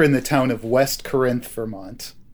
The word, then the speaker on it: Corinth: It is Corinth. – Some of the locals would say that Corinth is the original way to say it.